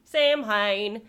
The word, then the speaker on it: Samhain: And this is a fun word that is not at all pronounced how it is spelled. – How is it spelled? – S-A-M-H-A-I-N. – Samhain. – Samhain. No, it's Samhain.